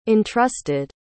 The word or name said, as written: entrusted